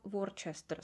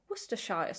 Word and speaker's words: Worcestershire: Takže přítelkyně, až spolu pojedeme na britský ostrovy, tak si hlavně nikdy nebudeš objednávat Worcester sauce, ale budeš si objednávat Worcester o Worcestershire sauce. – Proč? – Ale neboj, nejsi jediná, která to komolí, protože Worcester sauce je opravdu velmi často chybně vyslovovaný slovíčko.